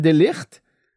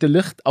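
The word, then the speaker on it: de Ligt: Die Bayern sind interessiert an Delicht. Ähm, an Licht? So heißt er, glaube ich. De Licht. Ich glaube, das ist Holländer. Und ich glaube, der wird der Licht ausgesprochen.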